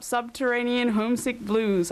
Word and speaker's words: Subterranean Homesick Blues: That was, and I'll probably stuff it up, Subterranean Homesick Blues.